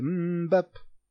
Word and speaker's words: Mbappé: Mais, donc, doublé de Mbappé. Ça marche pas. Il y a une syllabe de trop. C'est Mbappé.